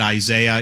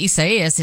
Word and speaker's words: Isaias: So, you know, I'm calling it Isaiah. You guys can call it what you want. I know that's not how you pronounce this.